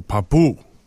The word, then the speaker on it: Papou: I'm going to, Papu, P-A-P-O-U. – Accent on the second syllable. – Second syllable, Papu.